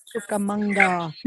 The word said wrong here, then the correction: Rancho Cucamonga: I don't even know if I pronounced that right. Yes. – Yeah. Rancho Cucamonga.